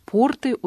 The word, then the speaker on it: порты: «Порты» — ударение на первом слоге. А если ударение на второй слог, то это штаны, которые носят крестьяне.